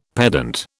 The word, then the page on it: pedant: British English